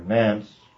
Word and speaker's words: Nantes: And the persecution was brought to an end by the Edict of Nantes, or Nance, rather, would be the pronunciation.